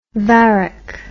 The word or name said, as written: varic